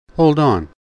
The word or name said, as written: hold on